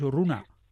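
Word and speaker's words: Urrugne: ¿Cómo pronunciáis correctamente? Yo decía Urrun, vosotros decís Urruna. – Bueno, en francés. – Ah, no. – Urrun. – Entonces lo voy a dejar como lo he dicho yo, en Urrun.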